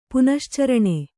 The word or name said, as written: ಪುನಶ್ಚರಣೆ